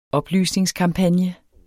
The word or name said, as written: oplysningskampagne